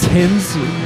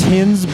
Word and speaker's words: Hinz: It's Hinz, but people say Hinz, so I don't really give a fuck, to be honest.